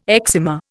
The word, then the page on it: eczema: Indian